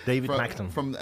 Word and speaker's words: David Magton: David Magton.